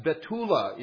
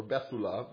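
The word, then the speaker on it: Betula (בתולה): The technical word for virgin, meaning a maid that's never been with a man, that's Betula in Hebrew, or Bethula, but the H is silent generally in modern Israeli or Hebrew pronunciation.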